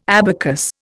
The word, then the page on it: abacus: Indian